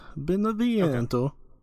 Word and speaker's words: Beneviento: time that the last time that we talked about it, you and I, we both got up through House uh, Beneviento. – Uh-huh. Is that how you say it? Yep, Beneviento. Okay.